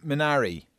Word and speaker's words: Minari: Your next movie pick, John, Minari.